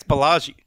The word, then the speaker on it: Ballage: Do I got that right? – I think it's Ballage. – I had no chance. B-A-L-L-A-G. Ice Cube, do you know the pronunciation on that one? – It's what you said, man.